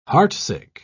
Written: heartsick

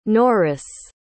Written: Norice

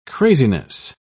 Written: craziness

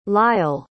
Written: lyall

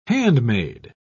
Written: handmade

